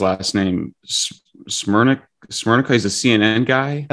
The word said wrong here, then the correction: Smerconish: Oh, Smerconish, um, sure. – Smerconish, yeah.